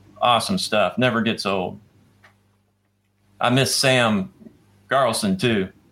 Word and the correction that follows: Garson: Did I say his name right? – Garson. – Yeah, Garson. I said it backwards there.